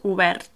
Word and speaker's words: obert: And again, you don't really pronounce, just because I'm showing Alex the words as well, but we don't really pronounce that T at the end. Obert.